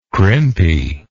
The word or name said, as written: crimpy